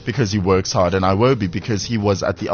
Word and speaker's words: Iwobi: He says that both his idols, Cristiano Ronaldo and Alex Iwobi, are his heroes. Did I pronounce it correctly? Iwobi? – Yeah, Ronaldo, yeah. – No, Alex Iwobi, yes, Iwobi, yes, thank you.